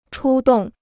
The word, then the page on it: 出动: chū dòng